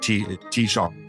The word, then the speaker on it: Taoiseach: And I think that's also isn't that what they say in the Department of Foreign Affairs that it should be uh, like T T shop.